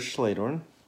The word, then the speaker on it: Schledorn: My name is Jared Schledorn.